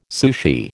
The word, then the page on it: sushi: American English